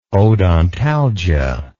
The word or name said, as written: odontalgia